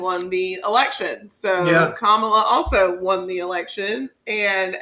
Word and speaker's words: Kamala: So Kamala Harris, her name is pronounced Kamala. – Harris is how you pronounce the last name. – Well, everybody can say Harris, but people can't say Kamala for whatever reason.